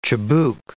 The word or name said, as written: chibouk